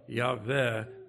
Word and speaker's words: Yahweh: People say Yahweh, it's Yahweh, really, the closest we can get to it, because in spoken Hebrew, there's no W sound, no W sound. What we would call W is pronounced V, so Yahweh would be closer to it.